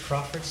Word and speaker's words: Crawford: Randy Crawford, C-R-A-W-F-O-R-D.